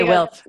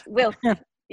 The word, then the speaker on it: WILPF: You say WILPF. – WILPF.